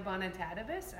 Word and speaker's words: Bonitatibus: My name is Tanya Bonitatibus.